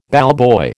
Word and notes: ballboy: American English

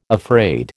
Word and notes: afraid: American English

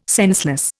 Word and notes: senseless: Indian

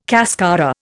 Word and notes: cascara: Indian

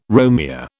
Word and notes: Romeo: British English

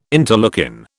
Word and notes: interleukin: British English

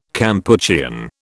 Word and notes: Kampuchean: British English

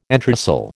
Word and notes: entresol: American English

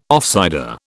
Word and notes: offsider: British English

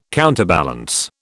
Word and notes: counterbalance: British English

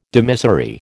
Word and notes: dimissory: American English

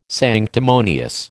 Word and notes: sanctimonious: American English

